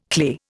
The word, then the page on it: clay: Indian